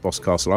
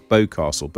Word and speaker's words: Boscastle: I have to say, though, Ben, it's quite useful to know that Boscastle is pronounced Boscastle. I always thought it was Bowcastle, but there you go.